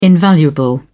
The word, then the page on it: invaluable: British English